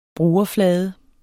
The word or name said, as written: brugerflade